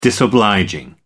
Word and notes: disobliging: American English

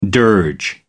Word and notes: dirge: American English

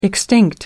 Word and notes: extinct: American English

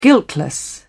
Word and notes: guiltless: British English

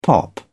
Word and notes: pop off: British English

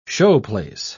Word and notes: showplace: British English

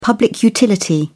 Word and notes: public utility: British English

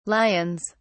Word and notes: lions: British English

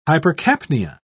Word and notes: hypercapnia: British English

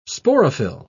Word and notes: sporophyll: British English

